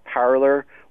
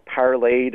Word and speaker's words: Parler: I know there's this new app that's kind of exploding out there called Parler or Parlay, depending on how you pronounce it.